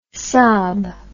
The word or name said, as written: saab